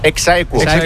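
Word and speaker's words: ex aequo: ¿Cómo se dice? – Exaequo. – Así se dice.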